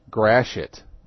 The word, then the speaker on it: Gratiot: Someone said Gratiot, G-R-A-T-I-O-T, east side of Detroit is pronounced Grashit. Grashit and shit by the locals.